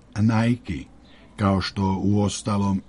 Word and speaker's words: Nike: Tačan odgovor je kao Palmolive Garnier i Nike.